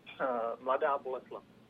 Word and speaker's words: Mladá Boleslav: Mladá Boleslav.